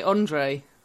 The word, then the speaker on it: Andriy: However, Andriy Taran, come on, am I pronouncing that right? – I'd say Andre, maybe. – Andre, okay.